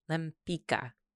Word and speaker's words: Lempicka: Tamara de Lempicka? Mm-hmm. Lempicka? Lempicka.